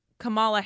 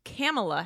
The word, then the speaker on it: Kamala: As Kamala Harris. But then I watched an SNL sketch that said it's pronounced Kamala Harris. Like Pamela, but Kamala.